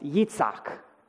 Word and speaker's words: Yitzhak: Or maybe you've heard it pronounced Yitzhak in its original sort of pronunciation, which is the Hebrew word for laughter.